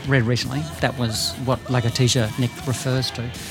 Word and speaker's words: Lagartija: It was just because I'd worked out how to pronounce Lagartija. – You were okay with Nick. – It's kind of like alopecia.